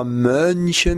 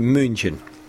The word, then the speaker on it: München: Et puis, une petite erreur aussi sur la voyelle de la ville où il habite, c'est pas München, mais un U, c'est München.